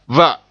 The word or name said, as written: va